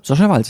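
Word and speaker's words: Sasha Waltz: Sascha Walz, ja, auf jeden Fall.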